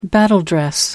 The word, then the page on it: battledress: American English